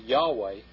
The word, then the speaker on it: Yahweh: This quote says it is generally acknowledged that Yahweh is the correct pronunciation of the memorial name of God proclaimed at the bush. Philologists and theologians agree in the term Jehovah, frequently used instead of Yahweh, is a spurious form of the word."